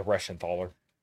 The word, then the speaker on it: Reschenthaler: So you mentioned, how do you say his name? Guy Reschenthaler? – Yeah, Reschenthaler.